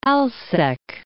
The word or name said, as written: Alsek